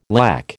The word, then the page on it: lack: American English